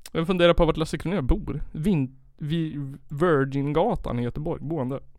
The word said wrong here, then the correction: Virginsgatan: Virginsgatan. Det kan inte uttalas på annat sätt än Virginsgatan.